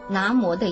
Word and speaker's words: 南无: 这是印度话，应该念作南无的音，就是皈依、依托、恭敬的意思。